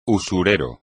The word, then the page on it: usurero: espagnol